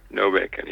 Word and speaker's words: Nobacon: Is it just No Bacon, like BLT, hold the bacon? – Yeah, No Bacon.